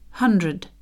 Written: hundred

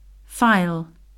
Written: file

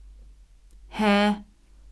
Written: hare

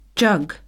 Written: jug

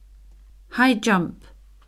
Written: high jump

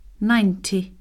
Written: ninety

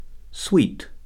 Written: sweet

